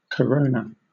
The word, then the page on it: corona: British English